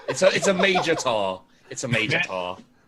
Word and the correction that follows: Minotaur: Minotaur. I said it wrong. My bad.